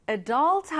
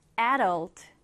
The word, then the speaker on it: adult: 자, 이 단어는 특이하게도 발음하는 방법이 두 가지입니다. 쌤이 방금 발음했던 것처럼 adult 하셔도 되고요. 앞에 강세를 두어서 adult, adult 이렇게 하셔도 됩니다. 자, 그러면 우리 정확한 스펠링을 같이 한번 써볼게요. adult, A-D-U-L-T, adult.